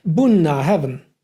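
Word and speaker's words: Bunnahabhain: Bunna Heaven, die Mündung des Flusses.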